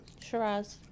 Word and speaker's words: Shiraz: Shiraz. – Is it Shiraz? – Mm-hmm.